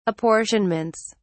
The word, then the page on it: apportionments: British English